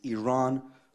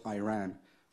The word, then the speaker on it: Iran: You support the Iranian people, but you're pronouncing our country's name wrong. Our country is named Iran, not Iran.